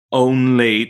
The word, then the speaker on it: only: Now, the tricky thing that I find with some of my American clients is then trying to put this O before an N sound, because typically only doesn't have an O.